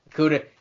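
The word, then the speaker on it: Okudah: Okuda.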